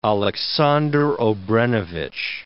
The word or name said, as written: Aleksandar Obrenovic